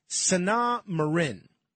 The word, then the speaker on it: Sanna Marin: So if you haven't heard about this, in a leaked video, Finland's prime minister, I believe her name is pronounced Sanna Marin, she's a young woman, relatively attractive, 36 years old.